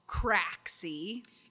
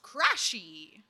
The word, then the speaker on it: Craxy: Next up, we have a group who debuted in March on the 3rd called Craxy. – It's pronounced crashy. – Oh, it's crashy. – It's supposed to be like crazy, but crashy. – Okay, so it's capital C-R-A-X-Y.